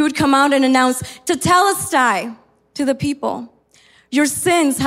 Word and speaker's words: tetelestai: It is pronounced as tetelestai.